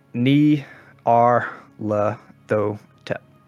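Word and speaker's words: Nyarlathotep: This ritual is written in great detail that the house and the land itself was consecrated to what says Ni Ar La Tho Tep, spelt out in many syllables like that. Ni-Ar-La-Th-Otep. O-T-E-P.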